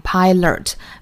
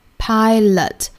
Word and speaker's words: pilot: 那这句话我想要讲到最重要的点呢，就是最后一个单词 pilot， pilot，一定要注意，不要读成 pilot。第二个元音千万不要读成卷舌的 r，应该是元音 a pilot，舌头千万不要卷得过多。